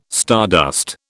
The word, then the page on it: stardust: British English